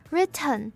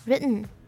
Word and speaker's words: written: 所以这个字我们不会念成 written，而是会念成 written。